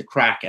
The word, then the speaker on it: craic: But I'm curious to know, we often talk about this when we have Irish guests on about the crack, and just to clarify for some of our non-Irish listeners, that's spelled C-R-A-I-C and it's not the narcotic variety, as I always say.